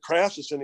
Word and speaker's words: Krafcisin: I said, "Krafcisin."